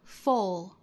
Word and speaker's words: full: 那 F-U-U-L 读成 full, full, full, 其中包含的是松元音的 o, o 的发音。整个唇周呢要叫 full 的 u 的发音要更松一圈。那当然很多老派的英国人也会把 F-U-L-L 读成 full,